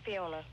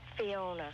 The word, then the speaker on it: Fiona: It's Fiona. – How do you say it? – Fiona. – Fiona, oh, okay. – Like Brigadoon.